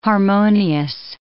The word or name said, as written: harmonious